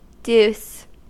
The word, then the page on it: deuce: American English